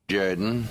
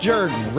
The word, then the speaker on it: Jordan: It's pronounced Jordan. – Yes! Yes! Yes! – This is Shug Jordan, football coach of the Auburn Tigers. – It's pronounced Jordan.